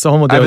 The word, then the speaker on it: Homo Deus: Homo Deus, is that right? – Yes, Homo Deus.